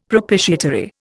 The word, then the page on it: propitiatory: Indian